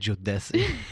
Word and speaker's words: Jodeci: The next one, I could be pronouncing this wrong, but Jodeci. – Oh, God, bro, this is like embarrassing. – The R&B quartet. – How did you say it? Jodeci. – Like an Italian name.